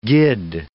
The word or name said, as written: gid